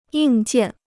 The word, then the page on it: 硬件: yìng jiàn